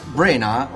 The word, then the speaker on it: Branagh: Insomma, l'anima del poverone, appunto, è Sir Kenneth Charles Brenna, ho scoperto che si pronuncia. – Brenna, come Edinburgh.